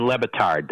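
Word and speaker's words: Le Batard: Yeah, and that was Dan Lebatard. That's how you pronounce his name.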